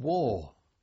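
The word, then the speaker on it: Waw: But and then from there, I think we went back up the road to a place called War. W-A-W, I think it's pronounced, spelled rather.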